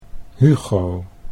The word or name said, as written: Hugo